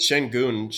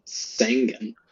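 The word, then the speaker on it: Şengün: I was trying to buy up some Alperen Shengun. – Is that how you pronounce that last name? – Yeah. – Shengun? – Yeah. – Yeah. – Oh, okay. I've always been saying Sengun.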